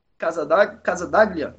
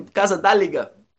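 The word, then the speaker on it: Casaldáliga: As fronteiras que, como dizia o Pedro, o Pedro Casadaglia, né? acho que é Casadaglia, eu esqueço, eu não consigo pronunciar o nome, Casadáliga, né?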